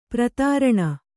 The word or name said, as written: ಪ್ರತಾರಣ